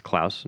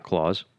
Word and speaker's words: Klaus: Well, Klaus with a K is pronounced Klaus, not Claus.